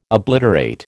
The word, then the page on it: obliterate: American English